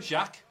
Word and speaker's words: Jacques: Jack Rougeau was there. Jacques Rougeau Jacques.